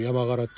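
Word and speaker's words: 山雀: あの、山スズメって書いて山柄って読むんですけども、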